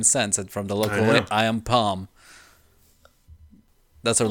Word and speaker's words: AMPM: It's spelled AMPM, Am Palm.